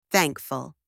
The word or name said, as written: thankful